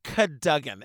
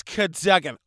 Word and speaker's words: Cadogan: Pronounced Cadogan. It looks like Cadogan, but it's Cadogan.